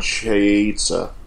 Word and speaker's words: Chazelle: And I believe it's pronounced Damien Chazelle. – Chaza. I think you're right.